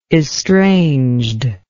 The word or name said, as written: estranged